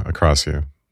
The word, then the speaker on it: akrasia: And it just seems like this is so close to to the akrasia. How do you say it? – Yeah, I think it's, yeah, akrasia. Akrasia?